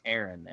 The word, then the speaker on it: Aron: I said, my name is Aaron, and it's spelled A-R-O-N.